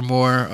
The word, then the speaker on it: Moore: More spelled with two O's like my last name, Mr. Moore.